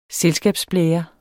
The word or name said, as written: selskabsblære